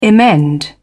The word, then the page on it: emend: British English